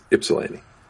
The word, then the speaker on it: Ypsilanti: And just to help you out a little bit, it's pronounced like an I. It's Ypsilanti.